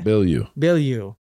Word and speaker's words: Bilyeu: How do you say it? – Bilyeu.